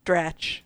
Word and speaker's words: Dratch: Dratch. – Dratch returned for Philadelphia versus New England showdown.